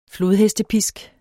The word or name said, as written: flodhestepisk